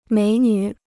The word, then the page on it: 美女: měi nǚ